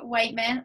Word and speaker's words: Whitman: Her name is Christy Whitman.